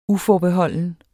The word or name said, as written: uforbeholden